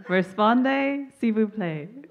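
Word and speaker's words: répondez s'il vous plaît: Respondez, s'il vous plaît.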